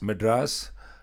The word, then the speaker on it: Madras: Now, am I going to say this right? Madras. – Madras, India.